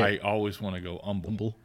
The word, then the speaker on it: Humble: of it's pronounced Humble to H. It's Humble. Silent, yes.